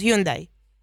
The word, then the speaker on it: Hyundai: Y es que resulta que esta marca de carros, a ver si lo pronuncio bien, Hyundai, nosotros decimos Hyundai.